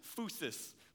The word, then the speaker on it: phusis: It comes from one of my favorite Greek words because it makes me think of Mr. T every single time, and it is phusis. Phusis, okay?